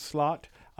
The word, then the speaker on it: van der Sloot: I do want to talk about Johan Vandersloot. Um, uh, I've also heard it pronounced slot. Uh, Johan Slot.